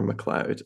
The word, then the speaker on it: MacLeod: McLeod, and McLeod is spelled M-A-C-L-E-O-D.